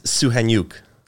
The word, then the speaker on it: Soukhaniouk: It's Soukhaniouk.